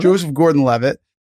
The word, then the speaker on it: Joseph Gordon-Levitt: Joseph Gordon-Levitt, as they are extractors, which in this realm is a military developed technology.